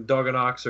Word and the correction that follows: Donio: or I probably butchered that last name. – Donio, but, yep. – Donio, yeah, I knew I did.